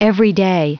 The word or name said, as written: everyday